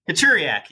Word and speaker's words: Kaczoriak: Kaczoriak, yeah. It's scary, but it's phonetic.